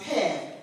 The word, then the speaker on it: Peh: The Hebrew letter for 80s is pronounced Peh.